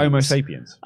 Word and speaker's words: Homo sapiens: Amos Sapiens. – Homo Sapiens. Come on, mate.